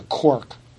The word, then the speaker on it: quark: Most of us have taken at least a look at this thing called the quark, right? Q-U-A-R-K. Did I say it right? Quark. – Quark.